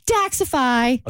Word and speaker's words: Daxxify: But yeah, props goes to Dr. E at Atlanta Face and Body and Daxify. – Okay, spell it for me. – D-A-X-X-I-F-Y.